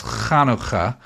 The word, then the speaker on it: Hanukkah: So the the first thing is that it's it's uh, it's you know the American pronunciation is Hanukkah, but the actual Hebrew you got to do that ch. So it's Hanukkah,